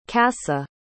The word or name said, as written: kasa